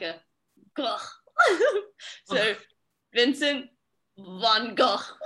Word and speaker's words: Van Gogh: Do you have the correct pronunciation of Van Gogh? – Yeah, yes, I do.